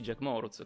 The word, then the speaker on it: Jack Moruz: Tanti mi chiedono, ciao. ma come si pronuncia? Ma non è un nome scandinavo, è Jacopo Moruzzi, Jack Moruz.